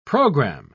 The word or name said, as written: program